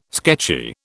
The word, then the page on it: sketchy: British English